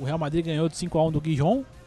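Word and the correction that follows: Gijón: Guijon, Guijon, como é que fala isso aí, Dani? Você que manja do é, Sporting. É, Rijon, Sporting Rijon.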